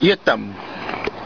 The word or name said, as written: yaati